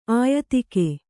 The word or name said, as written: ಆಯುಧಿಕ